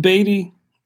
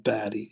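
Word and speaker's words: Baty: Is it pronounced Beatty, or do we know? – Yeah. – Okay. Yeah. I thought, but it's just spelled Batty.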